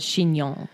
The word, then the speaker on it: chignon: I always wondered why he was called Chignon. – Yeah. And no one knew how to say it. And I was like, oh, it's chignon, like a hair. Bun, like yeah.